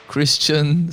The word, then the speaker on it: Christian: Christian, C-H-R-I-S-T-I-A-N, like the religion. – Yeah. – Yordanov, Y-O-R-D-A-N-O-V, like you had enough.